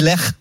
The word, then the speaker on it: Lech: Alors, ça se dit Lech, mais voilà, je vais pas prononcer à l'allemande, je dis Lech.